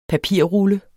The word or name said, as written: PAPIRRULLE